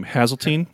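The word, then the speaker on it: Haseltine: I'm not even sure how to pronounce his last name. Hazeltine. – Yeah. That's how.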